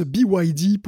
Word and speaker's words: BYD: On a un autre petit retour d'Erwan, qui s'est retrouvé à deux doigts de la crise de nerfs en m'entendant prononcer BID, le constructeur chinois de voitures. En fait, ça se prononce BYD pour Build Your Dream.